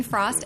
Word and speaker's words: Frost: Oh, sorry, Doreen Frost, F-R-O-S-T, not related to Jack Frost, and I'm the executive director of the Board of Accountancy.